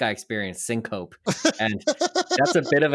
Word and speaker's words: syncope: So loss of consciousness in the medical community is known as syncope, S-Y-N-C-O-P-E, a word that does not look the way that it's pronounced, but trust me, it's syncope. And wait, not syncope? – Yeah, don't call it syncope.